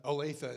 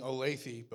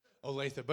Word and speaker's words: Olathe: Olathe is how you say it, right? Correct. I was saying Olathe before we came here. My dad told me, he said, yeah, that's not how you say it. So, Olathe.